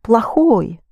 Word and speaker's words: плохой: You spell плохой, but you pronounce плохой. Think of plague.